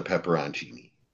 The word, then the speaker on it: pepperoncini: That's a pepperoncini. Pepperoncini. That's how you say that properly, people.